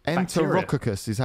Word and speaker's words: Enterococcus: So Enterococcus is Enterococcus is how you pronounce it.